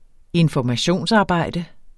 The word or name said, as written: informationsarbejde